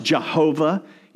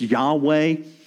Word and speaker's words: Yahweh: Now, it, has been pronounced Jehovah, you know, Jehovah Jireh, uh, but as, as scholars have moved toward trying to better understand what possible vowels could be used here, most people say it's Yahweh.